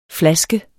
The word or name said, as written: flaske